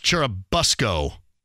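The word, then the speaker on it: Churubusco: Hey, Corbin says it's pronounced like bus, Cherubusco.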